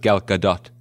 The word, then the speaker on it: Gal Gadot: By the way, it's pronounced Gal Gadot. I read that.